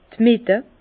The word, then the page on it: d' Mitte: Bas-Rhin; Herrlisheim